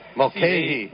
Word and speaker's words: Mulcahy: Excuse me, how do you think you pronounce this? – Mulcahy. – Like, I'll ask the guy that's been doing this for a few years. – Father Mulcahy. – Well, I screwed you up, too, when I put the spotter board sheet together for you. I'll take a hit for that, because I had pronunciations on the other sheet. – Yeah, it says Mulcahy.